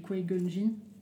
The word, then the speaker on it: Qui-Gon Jinn: Tu prononces comment tu dis Qui Gon Qui qui-Gon Jinn. ah, c'est Qui-Gon Jinn, ouais, si, si, ouais, c'est bien, je, Et ce que j'allais dire, dans la menace fantôme, au final, c'est vrai que c'est très centré bas sur.